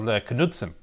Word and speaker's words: Knudsen: A guy called Knudsen. – Yes, probably Knudsen, the most important. Yeah. It's a silent K. My bad.